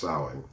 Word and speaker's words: Samhain: Actually, it's pronounced Samhain, okay? You've probably seen the word Samhain, S-A-M-H-A-I-N, but it's pronounced Samhain.